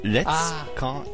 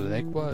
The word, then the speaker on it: lacquer: Ich weiß gar nicht, wie man das ausspricht. The lecker is off. Oder lecker is off. – The lecker is off. Na, der Lack ist ab. – Richtig. Spricht man das so? L-A-C-Q-U-E-R. – L-A-C-Q-U-E-R. – Was? E-Q-U-E-R. – Hab ich auch noch nicht gehört. – Hab ich auch nicht gehört. – Also, Lekwa ist ab.